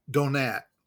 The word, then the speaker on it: Donat: Donat. It's like donut, but with an A.